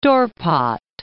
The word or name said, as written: Dorpat